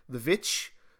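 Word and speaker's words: The VVitch: But Anya, she's also in a really good movie that I love, a good horror movie called The Witch, or the correct pronunciation of it, The Witch.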